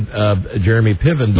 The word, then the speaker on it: Piven: Jeremy Piven, mm-hmm.